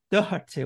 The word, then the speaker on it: Doherty: And I went, to be honest, pronounce it properly. Go on, Matt. – Matt Doherty. – Doherty, well done.